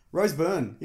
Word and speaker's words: Rose Byrne: Rose Byrne, you familiar with her? – Yes.